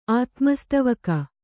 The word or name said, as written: ಆತ್ಮಸ್ತವಕ